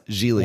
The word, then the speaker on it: Geely: It's pronounced Geely.